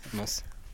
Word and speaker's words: Mens: Mince. – On dit mince, mais ça s'écrit M-E-N-S quoi. M-E-N-S, ouais, c'est ça, mince on dit.